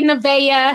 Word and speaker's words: Nevaeh: KK says, T, can you tell my daughter, Nevaeh? See, I know how to pronounce that because I know like three little girls named Nevaeh, honey. It's heaven spelled backwards.